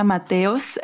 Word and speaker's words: Mateos: Well, yeah, my real name is Elena Mateos, but I go by Elena Matorg in every social media.